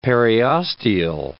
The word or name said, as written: periosteal